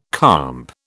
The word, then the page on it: comp: British English